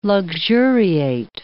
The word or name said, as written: luxuriate